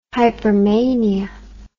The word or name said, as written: hypermania